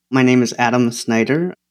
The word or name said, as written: Adam Snyder